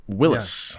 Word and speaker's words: Willys: I've been pronouncing Willys Jeep wrong this whole time. It's actually Willys Jeep.